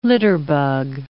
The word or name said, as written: litterbug